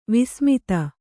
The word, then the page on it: ವಸುಮದ: Kannada